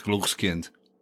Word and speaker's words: Glückskind: It's spelled G-L-U-X. K-I-N-D, but I guess in German it's Glückskind, that can even rock a baby to sleep, this baby carriage.